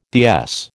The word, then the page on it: DS: American English